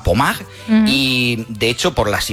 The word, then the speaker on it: Pommard: Se escribe Pommard, como si fuera en castellano, pero Pommard, pues allí en Francia, hablamos de unas imágenes.